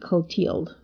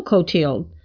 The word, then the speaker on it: Clothilde: C-L-O-T-H-I-L-D-E, Clotilde, is how I'm pronouncing it.